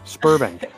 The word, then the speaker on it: Sberbank: Earlier this week, the ECB ordered the closures of Sberbank, because the B trips me up. – Like, you have to say Sberbank. We talked about this earlier. Like Sbarro's, the pizza place. Just pronounce it the same way you pronounce Sbarro's. Sberbank.